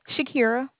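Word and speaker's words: Shaquira: Shakira. – Can you spell that for me? – Yes, it was going to be S-H-A, Q like quarter, U-I-R-A.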